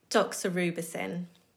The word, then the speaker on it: Doxorubicin: Doxorubicin.